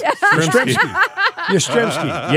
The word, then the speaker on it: Yastrzemski: Braves have signed Mike Yastrzemski to a 2-year, $23 million contract. Did I say it right? – You did. – Yes. – Yastrzemski. Yes.